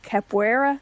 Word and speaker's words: Capoeira: How do you say Capoeira? – Capoeira.